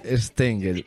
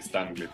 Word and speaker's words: Stengel: Es un nombre alemán. – Sí. Stengel. – Pero yo ando por acá como Stengel, para hacerlo más fácil.